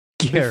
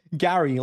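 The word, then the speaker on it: Gerry: Because Gary Ryan, the way you pronounce it, I don't know, but Gary. – Before the... Gary, like the snail from SpongeBob.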